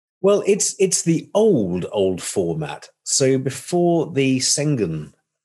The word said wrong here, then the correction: Schengen: treaty, I pronounced that wrong, haven't I? – Schengen. – Thank you. It didn't sound right to me.